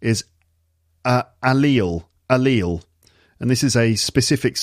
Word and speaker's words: allele: Oh, my alleles are hurting this morning. No, but it's a specific scientific term from genetic biology, and it's pronounced allele.